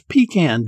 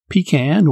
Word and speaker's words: pecan: Today is Friday, March 26th, and it's No Homework Day, International Waffle Day, as well as Pecan Day. Interestingly, that's a word with a couple of different pronunciations, pecan or pecan.